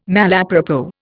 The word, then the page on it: malapropos: Indian